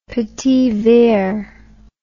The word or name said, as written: petit verre